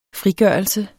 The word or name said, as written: frigørelse